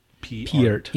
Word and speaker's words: Peart: Peart.